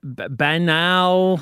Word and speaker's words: banal: I mean, banal. – Banal. Canal. – Fine. – Yeah. It's the classy way to have it when you pronounce it like canal.